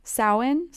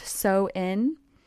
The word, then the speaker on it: Samhain: it's spelled so differently than how it's pronounced. It's S A M H A I N, but it's pronounced so in. So in.